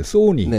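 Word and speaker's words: Sony: 발음이 소니는 네. – 전 세계 어디 가도 소니래요.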